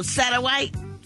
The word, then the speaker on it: Satterthwaite: It's a Satterthwaite. – I think the TH is silent. – Oh, Satterwaite? Okay.